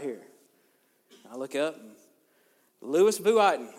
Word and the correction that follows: Louis Vuitton: And she's like, it's pronounced Louis Vuitton, genius.